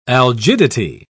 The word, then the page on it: algidity: British English